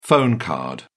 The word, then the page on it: phone card: British English